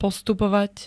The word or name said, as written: postupovať